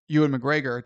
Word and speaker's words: Ewan McGregor: And so that Alec Guinness versus Ewan McGregor, did I say it right this time? – Yes.